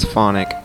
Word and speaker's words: Phonique: And I have to say, I thought it was Phonique, because that's the way it's spelled, but on the record, somebody says it's Phonic.